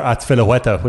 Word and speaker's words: Azpilicueta: I like Cesar Azpilicueta when you pronounce it properly like that, but nobody else does that, so he couldn't qualify.